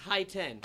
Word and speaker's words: Hiten: Okay, so it's at H-N Shah, H-N-S-H-A-H. – There you go. Next time I'll do it. – And my name's Hi-Ten. That's how you say it. That's not how you say it. That's how you spell it. H-I-T-E-N.